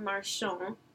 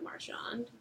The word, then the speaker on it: Marchand: Okay, his name is Robert Marchand, not Marchand, don't pronounce the D.